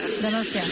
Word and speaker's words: Donostia: How do I say it? Donostia. – Donostia. – Donostia. Okay, yeah.